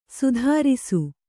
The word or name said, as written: ಸೈತಿರಿಸು